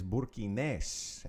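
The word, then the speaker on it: burkinés: And the nationality is Burkinese, accent on the last E.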